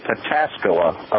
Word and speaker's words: Pataskala: Well, Sexy Crabtree probably resides in Pataskala, Ohio, which is near Columbus.